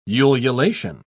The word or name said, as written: ululation